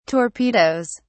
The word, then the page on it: torpedoes: British English